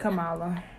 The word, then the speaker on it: Kamala: Congratulations, Kamala. Kamala. Jesus. I don't even know how to say her name. Kamala. – No, you did just. – I said Kamala. I did.